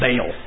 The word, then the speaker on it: Baal: I think in Hebrew it's Baal or Baal. But in English, you know, it's usually pronounced, and you look at the how it's pronounced in the English version of the Bible, it's Baal.